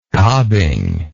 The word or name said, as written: cobbing